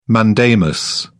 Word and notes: mandamus: British English

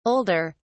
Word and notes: older: British English

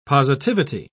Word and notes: positivity: British English

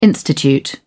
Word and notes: institute: British English

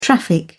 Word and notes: traffic: British English

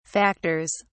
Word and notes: factors: British English

